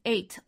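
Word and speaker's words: eight (אֵת): It's pronounced as eight, like you would say the number eight, but it's spelled as E-Y-T-H, eight, which simply means a sign of the definite direct object.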